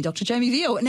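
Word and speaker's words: Veale: Thank you so much for coming along today, Dr. Jamie Veal.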